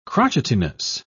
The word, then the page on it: crotchetiness: British English